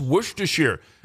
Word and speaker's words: Worcestershire: And this is the most accepted pronunciation in American English is Worcestershire. The key is to drop the first Y and soften the C-E, and I've always been able to say that one.